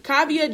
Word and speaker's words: Kaavia: It's a baby girl, Kav- Kavaya James. – Kaavia. Yeah. Excuse our mispronunciations. No, it's Kav- Kaavia James Union Wade.